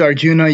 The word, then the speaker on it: Arjuna: It's a situation in which Arjuna, is that the right pronunciation? – Yeah, yeah. You can use Arjuna, you can use Arjun. The A can be dropped.